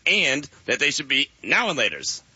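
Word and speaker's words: Now and Later: So I was just doing the two different pronunciations. I say now and laters. You say now laters like it's all one word.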